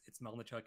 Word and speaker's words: Melnychuk: My personal Twitter's, you think you've got it on your screen, but for maybe people who are listening, it's Melnychuk 86.